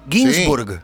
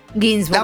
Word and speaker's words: Ginsburg: La G final es importante. – Sí. – Ginsburg. – Sí, no es como Ginsburg, psicólogo. Ginsburg.